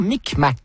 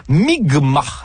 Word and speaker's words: Mi'kmaq: la nation Micmac, qui se prononce en fait Mi'kma, dans ce qui sera le premier contact.